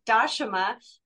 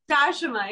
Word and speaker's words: Dashama: Just wanted to, my name is pronounced Dashama, but some people call me Dasha if it's hard to remember the pronunciation. – So yeah, one more time, Dashama. – Dashama, yeah, but you can call me Dasha.